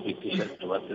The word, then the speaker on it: pitticedduoti: E salutiamo tutti i pitticedduoti. – Bravo, tutti i pitticedduoti, detto bene, dico che è in dialetto siciliano.